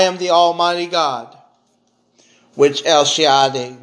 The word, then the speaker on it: El Shaddai: You pronounce that El Shaddai.